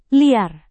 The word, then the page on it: liar: indonesian